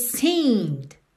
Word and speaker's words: seemed: That's the way it seemed. Seemed. Atenção que esse verbo está no passado, mas o E da terminação é d aí final não é pronunciado.